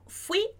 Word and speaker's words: fui: Em português, a gente fala fui. Em espanhol, a gente vai colocar a parte mais forte no i, como está escrito aqui. Seria como dizer fui, fui.